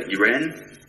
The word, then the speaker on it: Uren: People have come up with all sorts of pronunciations over the years. We usually go for Uren, but, you know, whatever fits you.